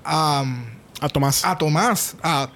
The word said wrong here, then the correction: Thomas: Thomas. es, que, como es TH, pues, y, la pronunciación que le hace en el workroom es Thomas y no Tomás.